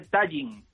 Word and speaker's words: Tallinn: se dice Tallinn. Acabado en una N.